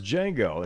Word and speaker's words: Django: And he goes, and there's Django, and he goes D J A N G O. The D is silent.